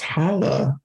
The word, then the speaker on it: Challah: And now that I know it's pronounced "hala," I get the "hala at Meg yes.